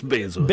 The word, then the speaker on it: Bezos: No, that's too bad for him. It's Bezos.